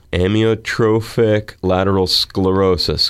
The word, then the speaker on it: Amyotrophic lateral sclerosis: Amyotrophic lateral sclerosis.